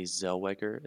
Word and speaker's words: Zellweger: I wanted to mention Renee Zellweger.